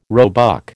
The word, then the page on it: roebuck: American English